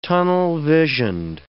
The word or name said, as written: tunnel-visioned